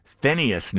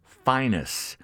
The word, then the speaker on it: Phineas: From Roy's 1958 album, We Three, the three being Roy, what some people call Phineas Newborn Jr. He preferred the pronunciation Phineas Newborn Jr. and Paul Chambers on bass, those three.